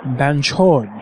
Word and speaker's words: Banchod: It is pronounced Banchod. Right? Yeah? Banchod. – Banchod. – Banchod. – Chod. – Banchod. – With the emphasis on the chod.